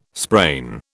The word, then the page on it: sprain: British English